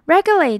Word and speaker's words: regulate: Regulate 在句子里因为为了念得快而顺，所以 u 的发音不会很明显。 gu 连起来念会有点像 ge 合起来念 g 的音。